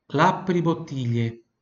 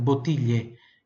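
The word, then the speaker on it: l'apribottiglie: Pronúncia pura do ti e o GL I tem som de LHI, tá? Então, botilhe. E o I aqui fica quase imperceptível.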